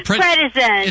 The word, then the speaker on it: prednisone: prednisone.